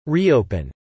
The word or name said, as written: reopen